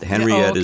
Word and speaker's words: Henriette: Henriette is the way it sounds. H-E-N-R-I-E-T-T-E. And Lazaridis is L-A-Z-A-R-I-D-I-S.